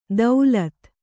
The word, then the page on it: ದೌಲತ್: daulat